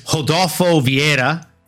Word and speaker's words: Rodolfo Vieira: So this is a Brazilian R, so you want to make sure that you say it like an H. So it's Rodolfo Vieira versus Armin Petrosian.